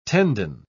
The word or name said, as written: tendon